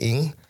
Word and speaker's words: Ng: And then, so the last name should be, the Chinese spelling is NG or pronounced ing. So, but coming from Vietnam, the Vietnamese way to pronounce it or spell it is NGO. So since we came from Vietnam, that's why it's spelled NGO, but it should be ing, or when you say the name in Chinese is ng, it's just sound.